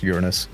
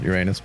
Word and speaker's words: Uranus: If you're interested in that article, it actually says Uranus and Neptune, and it's pronounced Uranus now. It's not Uranus, but I'm you know, my crude humor.